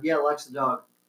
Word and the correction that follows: Doig: Doig. – Doig. – Yeah, Doig. Come on, guys, don't butcher her name.